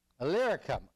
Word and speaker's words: Illyricum: I hate it when I don't pronounce a word right. Illyricum. Illyricum. There we go. Got it. Illyricum.